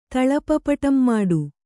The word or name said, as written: ತಳಪಪಟಂಮಾಡು